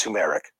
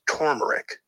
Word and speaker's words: turmeric: First of all, not pronounced turmeric, not even pronounced turmeric. – No, it's actually pronounced turmeric. Okay, there's an R after the U, turmeric.